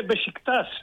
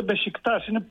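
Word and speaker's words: Beşiktaş: Προφέρεται Μπεσικτάς, δεν είναι Μπεσικτάς, κάνουμε λάθος στη Θεσσαλονίκη, δεν το κάνουν όλοι. Είναι η λέξη προφέρεται Μπεσικτάς, είναι η περιοχή στην Κωνσταντινούπολη. Και μου αρέσει,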